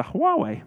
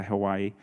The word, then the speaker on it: Huawei: It's a Huawei, is how I pronounce it. And some people say Hawaii.